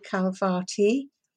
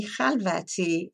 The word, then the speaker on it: Khalvati: My name is Mimi Khalvati, or Mimi Khalvati in first the English pronunciation, then the Iranian, and I tend to use the English one since I grew up with it.